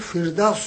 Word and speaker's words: فردوس: چنان که می دانیم عرب ها همیشه حرف پی را به طور فی تلفظ نکنند و از این رو پیر دیز رفته رفته نام باهای بهشت در آمد، در زبان عربی فردوس شد و ما هم امروز فردوس می گوییم.